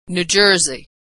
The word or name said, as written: New Jersey